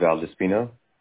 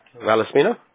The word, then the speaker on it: Valdespino: My last name is Valdespino. – Okay. Valdespino? – Valdespino.